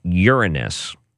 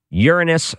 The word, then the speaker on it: Uranus: Mercury, Venus, Earth, Mars, Jupiter, Saturn, and then the U, she made us pronounce it Uranus, not Uranus, Uranus,